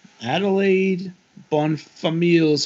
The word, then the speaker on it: Adelaide Bonfamille: She and her brothers live with their mother, Duchess, in Madame Adelaide Bonfamille's home in Paris.